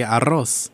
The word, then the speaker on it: arroz: He constantly told me, hey, you need to roll your R to say arroz. There you go. Arroz, arroz. Because he kept drinking, going like arroz, arroz, arroz, arroz. And it came out right.